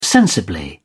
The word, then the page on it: sensibly: British English